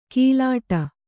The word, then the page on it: ಕಿಲಾಟ: kilāṭa